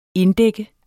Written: inddække

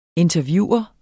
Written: interviewer

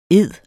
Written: eder

